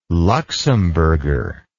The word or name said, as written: Luxembourger